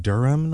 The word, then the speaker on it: Durham: Is it Durham, North Carolina? – Yep.